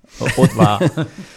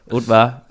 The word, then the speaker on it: Haute Var: Ut. Man udtaler ikke hovedet på ut fransk. – Haute Var. Haute Var.